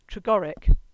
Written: Tregorrick

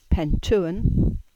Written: Pentewan

